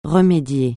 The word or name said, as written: remédier